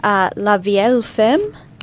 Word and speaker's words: La Vieille Ferme: Yeah, there's a $18 one here, La Vieille Ferme.